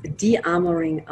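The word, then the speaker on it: De-Armoring: Ja, also tatsächlich wird es De-Amoring ausgesprochen.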